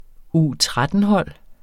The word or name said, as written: U13-hold